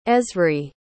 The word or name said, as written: ezri